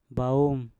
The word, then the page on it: baum: mpur